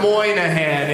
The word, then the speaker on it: Moynihan: Moynihan is it spelled out phonetically to begin with. Moynihan. It's the Y is there. It's as easy as pie.